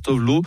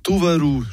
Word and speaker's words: Tove Lo: Alors, il y a ceux qui prononcent à la française, Tove Lo, ceux qui prononcent à l'anglaise, Tove Lo, et puis ceux qui prononcent à la scandinave, Tuvalu. Choisissez votre camp.